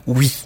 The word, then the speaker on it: Huy: Et de Bruxelles, on est allé vivre dans une petite ville qui s'appelle Uy. Ça ne s'écrit pas comme ça se prononce. Donc c'est H-U-Y.